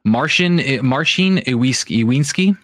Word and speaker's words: Marcin Iwiński: Marcin Iwiński.